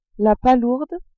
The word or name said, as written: palourde